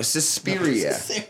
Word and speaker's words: Suspiria: Okay, anyways, so Suspiria. Caesarian. No, Suspiria.